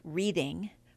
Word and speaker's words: Reading: It's spelled Reading, but pronounced Reading, as any of the workers at the 80 or so independently owned market stalls will tell you.